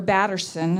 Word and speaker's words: Batterson: It's a devotion by Mark Patterson, or Batterson is how you say his name, called Draw the Circle.